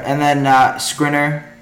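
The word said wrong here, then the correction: Škriniar: Am I saying that right? Skriniar. Vic- Skriniar. – Yeah.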